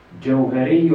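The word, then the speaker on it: جهري: Hur uttalas den här Johariyo? – جوهري الذكر جوهري الذكر يعني gör ذكر högt, inte tyst.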